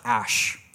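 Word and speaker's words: Æ: A-E, which is pronounced Ash.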